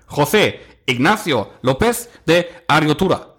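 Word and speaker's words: José Ignacio López de Arriortúa: José Ignacio López de Ariotura. Das ist aber gut ausgesprochen, würde ich behaupten.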